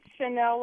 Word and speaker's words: Chanel: Hey, is it Chanel? Am I pronouncing that correctly? – Yes, it's Chanel like the perfume, and it's actually Fontana, California, which is in the Inland Empire.